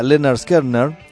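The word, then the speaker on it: Lynyrd Skynyrd: Esto se llama Leonard Skinner. Es un grupo que se escribe todo con Y.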